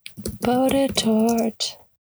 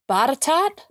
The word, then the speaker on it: Botetourt: Botetourt. Botetourt. Pronunciation. Botetourt? – Ooh, what? – Really? – That is not what I thought it was. – It is what possibly it is. Possibly the most non-obvious pronunciation in the bunch.